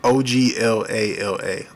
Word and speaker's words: Oglala: The Aglala. I'm not sure if I'm pronouncing this right. – Say it again. – O G L A L A. Oglala.